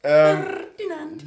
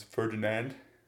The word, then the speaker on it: Ferdinand: Ferdinand. You could say it just like Americans, Ferdinand. – No, it's not fun. – Say it again, like how... – Ferdinand.